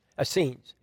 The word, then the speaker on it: Essenes: Essenes. Am I saying that right? Essenes.